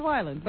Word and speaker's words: Weiland: Is it Weiland or what? – Weiland is pronounced. I thought. – Weiland, Weiland. – Okay. It's Weiland. But anyway. – The one who has a drug problem. – It's Weyland.